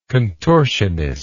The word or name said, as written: contortionist